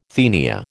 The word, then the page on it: tenia: American English